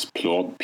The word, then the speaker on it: Plourde: It's Plourde, P-L-O-U-R-D-E.